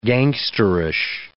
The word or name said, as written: gangsterish